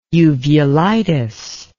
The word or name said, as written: uvulitis